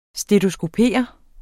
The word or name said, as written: stetoskopere